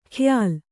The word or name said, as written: ಖ್ಯಾಲ್